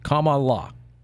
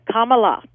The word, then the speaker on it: Kamala: Comma. Just think of comma, like a comma, an explanation comma. It's Kamala. – Gotcha. Kamala.